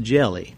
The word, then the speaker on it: Diali: Senegal It's spelled D-I-A-L-I, but it's pronounced Jelly.